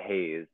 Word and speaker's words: Heize: And then um I would say Heize. Uh she's her name is spelled H E I Z E, not H A Z E, mm-hmm.